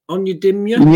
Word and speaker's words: Onyedinma: Onyedinma? – Yep.